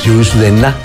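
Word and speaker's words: Yourcenar: Una frase de Marguerite Jursenar, escritora francesa. Entonces sería Jursenar, porque la R no se pronuncia, la R final.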